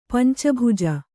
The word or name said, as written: ಪಂಚಭುಜ